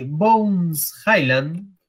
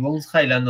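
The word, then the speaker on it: Bones Hyland: Y Bones Highland, espero estar pronunciando bien, si Bones Highland o Nayon. – 10 10, 10 No, no, pronunciaste bien, pronunciaste bien.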